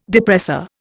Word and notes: depressor: Indian

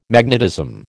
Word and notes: magnetism: American English

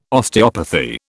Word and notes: Osteopathy: British English

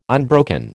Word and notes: unbroken: American English

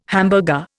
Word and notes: hamburger: Indian

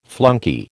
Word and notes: flunkey: American English

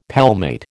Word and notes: palmate: American English

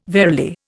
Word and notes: verily: Indian

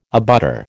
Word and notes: abutter: American English

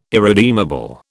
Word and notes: irredeemable: British English

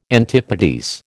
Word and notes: antipodes: American English

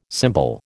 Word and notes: cymbal: American English

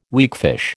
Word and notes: weakfish: American English

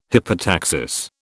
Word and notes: hypotaxis: British English

